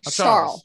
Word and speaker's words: Charles: Maybe an S, but he definitely doesn't say ch. Jesus. There's no ch. – There's no Okay. Charles. Because we've gotten some shit from mispronouncing his name. – And so we need that last name. Yeah. – You got it right from the horse's mouth. – Yes. It's a sh, not a ch.